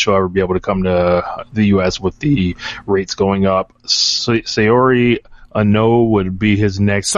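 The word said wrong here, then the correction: Saori: He doesn't, it's pronounced Sari. Yeah. Like, you sorry?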